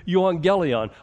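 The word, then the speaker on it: euangelion (εὐαγγέλιον): Good news, the word is euangelion. I get that it's two G's, but it's pronounced N-G in the Greek.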